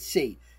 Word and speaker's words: Tse: She spells it T-S-E. But I think they pronounced it C.